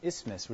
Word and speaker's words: isthmus: And Corinth, as you guys can see, is on what is geographically called an isthmus. That's how you say it. An isthmus, which is a narrow strip of land connecting two larger bodies of land.